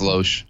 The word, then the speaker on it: Loesch: How do you say her? – I think it's Loesch. – Loesch, yeah. So basically,